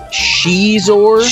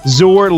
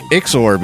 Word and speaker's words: Xizor: It's Xizor. – Xizor long O. – I called him like Ixor because I don't know how to.